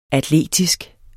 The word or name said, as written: atletisk